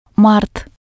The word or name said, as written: mart